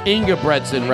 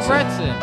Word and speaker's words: Ingebretson: Just go Inga. You know what Retson is from the shirts? Just say, instead of Retson, say Bretson. – Yeah. – Ingebretson, Retson. Ingebretson.